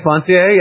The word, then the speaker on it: foncier: And I opened up my Encyclopedia Britannica, 1903, and I turned to look for credit mobiliere, and I find another word, credit foncier, I believe it is the pronunciation. – Okay, foncier, yes, okay.